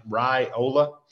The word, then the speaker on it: Raiola: Think about ham and cheese on rye, Raiola. That's the way you pronounce Dylan's name, the five-star, the number one overall prospect in the class.